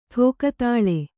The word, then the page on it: ಠೋಕತಾಳೆ: ṭhōka tāḷe